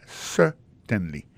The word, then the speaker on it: certainly: Alors, en parlant de accent, gardez bien pour un mot certainly, accent tombé sur le premier syllabe là. Yes, certainly.